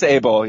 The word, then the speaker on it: Abel: And do you feel like you left your debate with it's Abel or Ebel? How do you pronounce that last name? – I believe it's Abel, yeah.